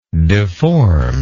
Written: deformedness

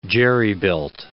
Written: jerry-built

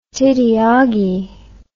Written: tiddy oggie

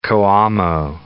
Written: Coamo

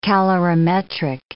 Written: calorimetric

